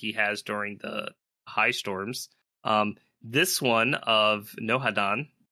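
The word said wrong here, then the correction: Nohadon: Did I say that right? – It's pronounced Nohat-on.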